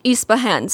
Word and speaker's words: Yspahan: Yspahan.